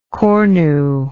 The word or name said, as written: cornu